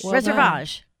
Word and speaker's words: Reservage: Is that how you say it? – Reservage. – Right? – Yeah. – Oh, I said it right.